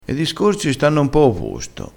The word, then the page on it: e discorsi: dialettale